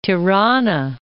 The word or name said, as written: Tirana